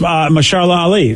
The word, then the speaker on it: Mahershala Ali: Oh, it's Mahershala Ali.